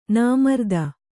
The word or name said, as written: ನಾಮರ್ದ